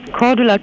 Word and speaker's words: Cordula: Mein Name ist Cordula Kine.